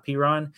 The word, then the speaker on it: Perine: They've got Samaje Perine, I think I'm saying that right, Perine.